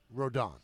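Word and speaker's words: Rodón: how do you pronounce the name? Rodon. It's Rodon, not Rodon.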